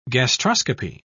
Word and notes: gastroscopy: British English

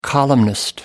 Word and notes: columnist: American English

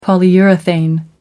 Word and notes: polyurethane: American English

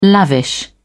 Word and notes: lavish: British English

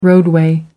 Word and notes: roadway: American English

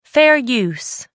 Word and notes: fair use: American English

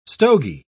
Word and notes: stogie: British English

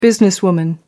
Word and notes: businesswoman: American English

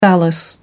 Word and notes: phallus: American English

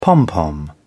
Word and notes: pompom: British English

